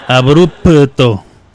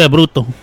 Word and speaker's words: abrupto: Abrupto. Es que hay que pronunciar bien la P porque luego se pierde. Porque luego piensan que uno dice abrupto, ah, No, no, es abrupto.